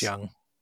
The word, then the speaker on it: Jung: It might be Carl Jung, actually. Jung. – Yeah, I think you're right. I think it is. – It's Jung. – Yeah, so sorry I mispronounced that.